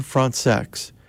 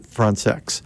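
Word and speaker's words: Françaix: Next, Trumpet Sonatina by Jean Frantzex. And that's the way he pronounced his last name. He thought Americans were actually kind of funny about their qualms about that pronunciation, but he would pronounce it Frantzex.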